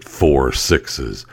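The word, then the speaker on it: Four Sixes: So it's a 6666 ranch, and they pronounce it 4 6's.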